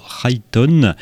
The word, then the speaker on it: Rhyton: Il s'agit de la formation Rhyton, comme on pourrait le prononcer à l'anglo-saxonne, ça s'écrit R-H-Y-T-O-N,